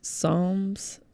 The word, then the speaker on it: Psalms: Psalms. It's a weird word. It's silent P.